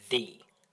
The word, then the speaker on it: ye: So what if would people have said ye old, or would they have said the? – No, they would have just said it. Like, a Y would have just been pronounced as the.